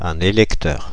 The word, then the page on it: électeur: [e.lɛk.tœʁ]; /e.lɛk.tœʁ/; Paris